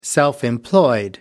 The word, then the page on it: self-employed: American English